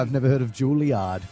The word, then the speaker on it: Juilliard: You'd need to get a gig at like Juilliard or something.